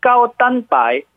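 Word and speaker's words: 까오단바이 (高蛋白): 발음은 띠지방 저지방 네. 고단백은 까오단바이 매미 저지방 고단백 음식입니다.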